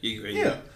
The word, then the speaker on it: Yggdrasil: Yggdrasil? – Nah, nigga, that ain't how you say it. it is. I don't know. You're right. Yggdrasil? – Yeah, Yggdrasil.